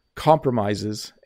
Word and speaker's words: compromises: Notice compromises. It's a weird word, right? Because it looks like it has the word promise in it. and I've heard English learners say it incorrectly. So when you make a promise, it's pronounced promise, but when you compromise, it's pronounced compromise.